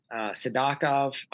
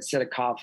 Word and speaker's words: Sidakov: He says Sadakov. I'm going to say Sadakov. I said Sidakov forever. Mm-hmm. I'm going to follow Belaglotsov there. I'll say Sadakov. So I knew Sadakov was going to move up.